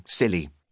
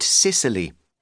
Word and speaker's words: Scilly: Scilly. No sniggering. It may be pronounced the same as silly with a silent C, but giggle at your own peril. Nor is it Sicily, which has considerably more takeaway pizza outlets, mafia, and donkeys.